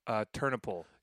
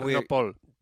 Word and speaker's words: Ternopil: Well, I mean, last night we were in, how do you pronounce the city we're in? – Ternopil. – So, anyway, so Ternopil. – Ternopil.